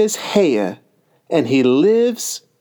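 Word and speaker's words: Haigha: His name is Heya. – He pronounced it as though to rhyme with mayor.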